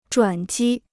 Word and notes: 转机: zhuǎn jī

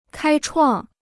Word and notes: 开创: kāi chuàng

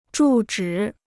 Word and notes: 住址: zhù zhǐ

